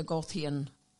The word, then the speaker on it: Goethe: And in terms of academic medicine, this follows the Goethean, I'm not sure if I'm pronouncing it right, Goethe, yeah. Sciences, Goethe, fine, G-O-E-T-K-E, yes.